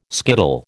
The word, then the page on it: skittle: American English